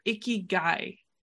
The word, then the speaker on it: Ikigai: And we've had different types of conversations related to this, but your Ikigai, did I say it right? – Yeah.